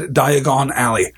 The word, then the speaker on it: Diagon Alley: I have always said Diagon Alley. I have put the stress on the last syllable of Diagon because it sounds more, I don't know, wizardy.